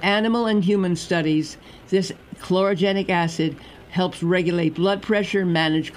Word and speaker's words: chlorogenic acid: They're not roasted, and these beans have more of a potent antioxidant called chlorogenic acid. Did I pronounce that correctly? – Sounds terrific to my ear.